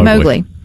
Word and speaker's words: Mowgli: Well, there in the family section of your streaming device says, is it Mowgli? Am I saying that correctly? – Yeah, Mowgli.